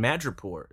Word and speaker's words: Madripoor: Hunt for Wolverine, Mystery, and Madripoor. Did I say that right? – Yeah. Cool.